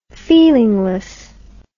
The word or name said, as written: feelingless